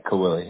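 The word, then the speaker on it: Caoili: C-A-O, C-A-O-I-L-I, Kaoili, yep.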